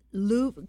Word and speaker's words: Louv: Richard Louv, can you say his last name or spell it? I'm sure I don't have it right. – It's L-O-U-V, Louv. – Okay. L-O-U-V. All right. That's perfect.